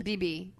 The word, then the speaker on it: Bebe: How do you pronounce that? – Wow. – B-E-B-E. Beeb? – BB. – BB. – I think it's BB.